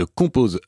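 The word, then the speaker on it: Chomposher: C'est notamment le cas de Composer, ça s'écrit C-H-O-M-P-O-S-H-E-R. C'est un jeu de mots sur le terme anglais composer qui veut dire compositeur et her, le pronom elle.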